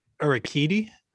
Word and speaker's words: Urquidy: They have that kid, Urique. What? How do you pronounce his last name? – I think it's Urquidy.